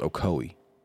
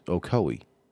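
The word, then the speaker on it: Ocoee: I think it's actually pronounced Ocowee. – Oh, I'm not going to try and butcher that name. – It's O-C-O-E-E. So, whatever.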